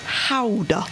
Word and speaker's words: Gouda: It's actually pronounced Gouda Dutch.